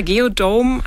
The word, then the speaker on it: Geodome: Und Sie haben da eine Besonderheit, die auch optisch schon ins Auge fällt, den Geodome, oder wie spricht sich das aus? – Ja, genau. Unser Geodome, also ist eine geodätische Kuppel, sieht so ein bisschen aus wie ein Iglu, nur durchsichtig.